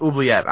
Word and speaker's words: Oubliette: Jerry, I'm telling you, man, go online, go on your computer, use your monkey paws, type it out, and go into Wikipedia has a place where they have someone pronounce it. It's Oubliette. I 100% I'm thinking of, there's another card, Ovulate, that is a magic card, that's what I was thinking of.